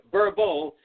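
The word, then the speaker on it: Vurbl: That's V-U-R-B-L. It's spelled or it's pronounced Verbal.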